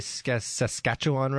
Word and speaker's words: Saskatchewan: Did I say Saskatchewan right? – You did very good.